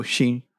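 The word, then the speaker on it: she (し): Oh, she. – And what is... – Or yon. You can say yon or she. – What is seven? – Seven? Nana. Or she.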